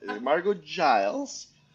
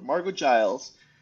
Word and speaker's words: Margeaux Giles: Margeaux Giles. My brain really wants to mispronounce it, but Margeaux Giles, CEO at IRYS Insurtech.